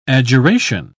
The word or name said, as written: adjuration